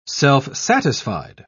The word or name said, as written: self-satisfied